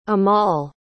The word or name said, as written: amal